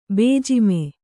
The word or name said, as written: ಬೇಜಿಮೆ